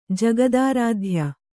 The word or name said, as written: ಜಗದಾರಾಧ್ಯ